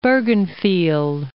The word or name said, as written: Bergenfield